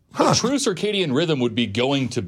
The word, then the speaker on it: circadian: There is real science behind the idea of the circadian rhythm and how important it is. None of us follow a true circadian rhythm anyway. – Circadian. Stop saying it wrong. Circadian. Both of you.